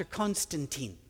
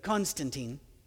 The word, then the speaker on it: Constantine: After Christianity began to blossom, and especially after Constantine, and yes, he would have pronounced his name Constantin, but Constantine, don't want to mess that up for everybody, accepted Christianity as the official religion, that's when the big break between Christians and Jews was formal and final.